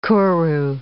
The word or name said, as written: kuru